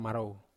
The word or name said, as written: marou